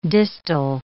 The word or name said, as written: distal